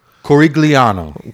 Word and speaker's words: Corigliano: It's Italian, yeah. So Corigliano.